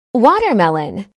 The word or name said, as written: watermelon